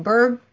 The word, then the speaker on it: Byrd: But Sandy is spelled with Sandee, Bird, BYRD.